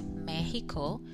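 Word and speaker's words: México: And it's very similar as well to Argentina, but in this case, Mexico in Spanish is with the he, the pronunciation Mexico.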